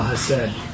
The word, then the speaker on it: chesed: It's like a B with a little E, R-I-T. I'm not sure how that little E works. – How would you do phonetically spell chesed? – It's H-E-S-E-D. – Chesed, okay. – So just think of two syllables. – When they pronounce it, it's like chesed. – Chesed, yeah, chesed.